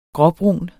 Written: gråbrun

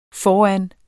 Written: foran

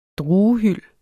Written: druehyld

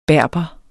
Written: BERBERE

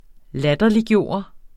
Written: LATTERLIGGJORDE

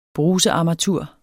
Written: brusearmatur

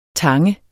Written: tange

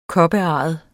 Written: koparret

